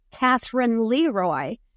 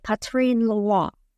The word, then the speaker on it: Catherine Leroy: Well, it looks to us English speakers like Catherine Leroy, but she pronounced it, of course, in French, because she was French. And I don't know about my French accent, but the best I can do is to say Catherine Leroy.